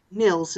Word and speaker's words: Nils: Is it Niles Swenson or Nils? – I think Nils is the right way to pronounce it.